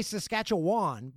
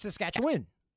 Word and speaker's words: Saskatchewan: Because most people say Saskatchewan, but it's Saskatchewan.